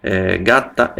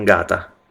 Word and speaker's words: gatta: Gatta è una parola abbastanza facile, ma attenti alle doppie. È gatta e non gata. Capito? Le doppie sono importantissime in italiano.